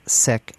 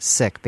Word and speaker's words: Sikh: And indeed, we have talked with guests, and they have all pronounced it sick and asked us to pronounce it sick, so that we are pronouncing it, or I am pronouncing it sick, based on members of the community preferring it that way.